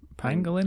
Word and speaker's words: pangolin: It's not Pangolian. – No. – It's not. – No. – I've been saying this wrong forever. – I think so, yeah. – Oh, yeah, no. It's definitely not Pangolian.